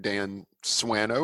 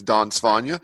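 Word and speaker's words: Dan Swanö: Yes, the anglicized version probably goes something like Dan Swano or Dan Swano. The Swedish pronunciation is Don Svanja.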